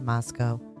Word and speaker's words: Moscow: that's the real life mystery that had enshrouded the pretty northern Idaho college town of Moscow, pronounced not like the Russian capital, but to rhyme with Costco.